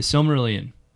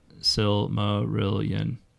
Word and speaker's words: Silmarillion: I thought Silmarillion was five syllables. – The Silmarillion. – Oh, I thought it was Silmarillion. – Well, I'm speaking it American style here, so...